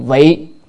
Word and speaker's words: vị: Rồi vị cầu pháp cố là vì cầu pháp cho nên, tức là vì chữ này đọc là vi hay vị, nhưng mà chỗ này phải đọc âm vị nha.